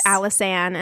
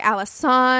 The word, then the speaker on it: Alisann: She says, sending love from Ireland, and her name is spelled Alisann, but pronounced yes. Alice Ann, and I love that. That's so cute. – We would definitely have pronounced it a couple of different ways before landing on Alice Ann, I think.